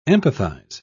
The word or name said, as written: empathize